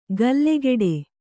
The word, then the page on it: ಗಲ್ಲೆಗೆಡೆ: gallegeḍe